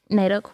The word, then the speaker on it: NAIDOC: So excuse the ignorance, but do you mind discussing a little bit around the Reconciliation Week and NAIDOC Week? Is that how you pronounce it? – NAIDOC Week.